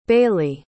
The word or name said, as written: baillie